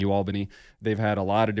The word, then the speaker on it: UAlbany: U Albany. – It's U Albany. I got that now.